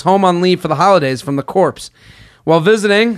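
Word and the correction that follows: Corps: The core? – It's pronounced core.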